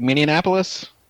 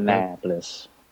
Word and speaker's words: Minneapolis: I try to throw an extra N in there, like Minneapolis. I don't know why. Always done it. Trying to stop. – Minneapolis. – Yeah. – Minneapolis.